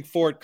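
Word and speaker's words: Forte: First of all, Steve, massive props to you for pronouncing Fort correctly without the diacritical that isn't there that I can't resist saying every time I say it.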